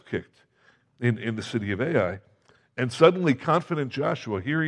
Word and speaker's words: Ai: Then they get to this city called Ai, or I, however you want to pronounce it. It's spelled A-I, so I pronounce it A-I.